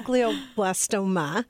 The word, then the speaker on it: glioblastoma: what is glioblastoma? – And Kim said it right.